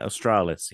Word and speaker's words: Australis: If you've seen the Aurora Australis, did I pronounce that right? – Yeah, Australis, yeah. Southern.